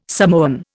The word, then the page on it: Samoan: Indian